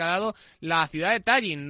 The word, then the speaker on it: Tallinn: se dice Tallinn. Acabado en una N.